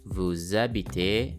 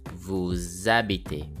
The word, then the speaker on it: vous habitez: You will see that there is a linking sound between the words vous and habiter. That's why the word habiter starts with a vowel sound and vous ends with an S. So we link these two words and it will sound like this. Habitez.